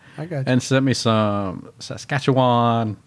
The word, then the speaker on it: Saskatchewan: Saskatchewan. – Oh, you actually said it right that time.